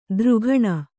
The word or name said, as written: ದ್ರುಘಣ